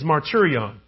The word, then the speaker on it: martyrion: So this Greek word for testimony is martyrion, which sounds like the word martyr.